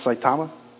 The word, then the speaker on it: Saitama: Did I say it right, Jan? Saitama? Close to that.